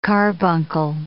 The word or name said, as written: carbuncle